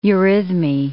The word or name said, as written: eurhythmy